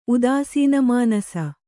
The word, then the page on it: ಉದಾಸೀನಮಾನಸ: Kannada